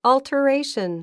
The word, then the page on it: alteration: ɔ̀ltəréiʃən; オルタレイション